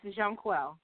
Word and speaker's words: Jonquel: Yes, Jonquel.